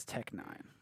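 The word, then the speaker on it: Tech N9ne: Yeah, it's Tech Nine.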